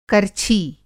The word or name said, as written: कर्छी